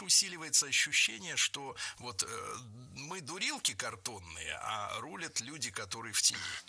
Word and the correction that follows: рулят: Не рулят, а рулят. Переносим ударение за корень и получаем правильный вариант. Рулят, рулит, рулим, рулишь.